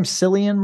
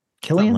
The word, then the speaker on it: Cillian: How do you say his name? Cillian Murphy? – Killian? Something like that. – Killian? – It's whatever the Irish pronunciation is.